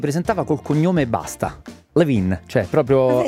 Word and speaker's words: Levine: E il cantante di Maroon 5, Adam Levine, o Levine che dir si voglia. – No, Levine, giusto, Adam Levine.